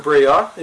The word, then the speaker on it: Breillat: Catherine Breillat, is that how you say it? – Yeah, the French director.